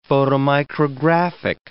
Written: photomicrographic

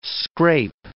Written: scrape

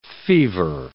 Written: fever

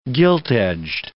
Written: gilt-edged

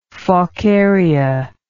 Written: faucaria

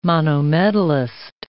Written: monometallist

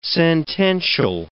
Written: sentential

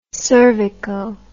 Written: cervico

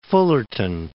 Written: Fullerton

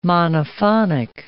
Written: monophonic

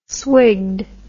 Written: swigged

